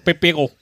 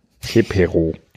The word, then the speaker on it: Pepero: Und wie spricht man es da aus? – Pepero. – Pepero.